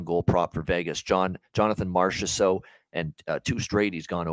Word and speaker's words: Marchessault: John Masserol, as Don Cherry once called him. Yeah. We always love the old Don Cherry name pronunciation mistakes. And yeah, that was one of the classic ones.